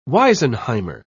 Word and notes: wisenheimer: British English

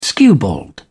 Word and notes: skewbald: British English

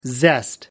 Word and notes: zest: American English

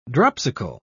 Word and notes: dropsical: British English

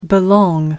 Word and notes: belong: American English

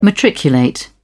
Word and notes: matriculate: British English

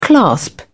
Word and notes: clasp: British English

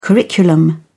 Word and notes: curriculum: British English

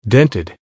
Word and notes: dented: American English

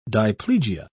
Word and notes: diplegia: British English

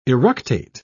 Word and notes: eructate: British English

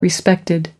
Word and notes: respected: American English

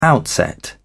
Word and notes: outset: British English

